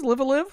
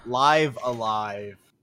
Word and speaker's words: Live A Live: Live a Live? – Live Alive. – No, it's Live a Live.